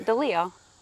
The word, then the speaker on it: DeLeo: DeLeo. – Like it's spelled. Yeah. Like it should be.